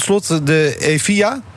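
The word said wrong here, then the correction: Evija: Zeg ik het goed? Of moet ik EVIGA? – Nee, het is geen Spaanse auto. Hij komt uit Engeland. – Eh, het is Ivaya. Het is Ivaya.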